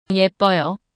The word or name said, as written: 예뻐요